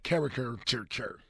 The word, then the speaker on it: caricature: I don't expect him to become that guy, but as long as he's not some character, how you say it? – Character? – Yeah, caricature. – There you go. Caricature.